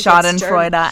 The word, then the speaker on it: Schadenfreude: It's Schadenfreude, and I learned that from Avenue Q.